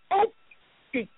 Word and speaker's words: osheq: A Hebrew word for oppression is pronounced okshik. Okshik. It's spelled O-S-H-E-Q, meaning oppression, extortion, to obtain from a person by force, intimidation.